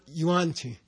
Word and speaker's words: Yuan Qi: Is it Yuan Qi? Yang Qi? Wan Qi. Yeah. How do you say? Yeah. Yuan. Yuan Qi.